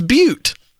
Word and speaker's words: Butte: Hey, Luke, I'm sorry I said it wrong. It's Butte.